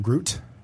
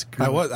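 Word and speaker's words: gruit: Is that how you pronounce it, as Gruet and not Groot? – Yes. I pronounce it Gruet, so I don't know.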